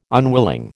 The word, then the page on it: unwilling: American English